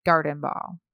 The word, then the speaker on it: Gartenbau: And remember, it's spelled with a T. So Gartenbau. Gartenbau.